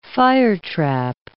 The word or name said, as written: firetrap